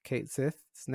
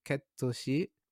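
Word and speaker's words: Cait Sith: And I guess in Japanese it's ketoshi, like I think it's based on like some Gaelic word or character, but they officially confirmed the English pronunciation, which is Kate Sith,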